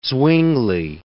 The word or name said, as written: Zwingli